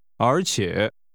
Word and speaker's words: 而且: 而且。 – Is pronounced with a rising and a falling rising tone.Say, besides, it sometimes snows.